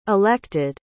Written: elected

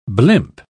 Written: blimp

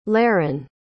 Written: leron

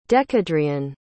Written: dekedrian

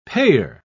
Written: payer

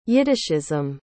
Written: yiddishism

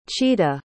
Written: chita

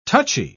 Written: touchy

